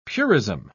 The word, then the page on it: purism: British English